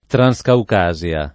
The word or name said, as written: Transcaucasia